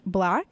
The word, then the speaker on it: 6LACK: You'll see it spelled like 6LACK, but it's pronounced Black.